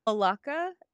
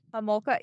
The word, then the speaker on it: Homolka: This can be seen in famous criminal duos such as Carla Holacka and Homolka. I'm so sorry if I butchered that. And Paul Bernardo and Fred and Rose West. – Homolka? – Homolka.